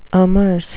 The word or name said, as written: amerce